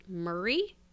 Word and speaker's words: Moray: I think it's spelled Moray, but I think it's pronounced Murray.